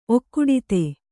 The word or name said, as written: ಒಕ್ಕುಡಿತೆ